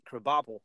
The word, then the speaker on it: Krabappel: Well, I always thought it was Krabappel. – No, well, I mean, that's how you pronounce it, Krabappel.